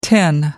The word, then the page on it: tin: American English